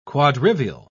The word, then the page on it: quadrivial: British English